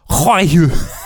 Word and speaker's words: Roaille: They pronounce her name like Hwaiyu. – R-O-A-I-L-L-E.